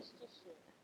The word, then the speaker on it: Worcestershire: I'm just like, Worcestershire.